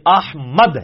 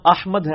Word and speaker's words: احمد: احمد لفظ تو نہیں, یہ احمد ہے, میم کے اوپر زبر ہے.